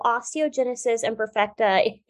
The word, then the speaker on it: osteogenesis imperfecta: So, osteogenesis imperfecta is a really long, complicated mixture of words.